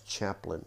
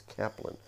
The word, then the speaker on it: Caplan: I always think it's Chaplin, but it's Caplan.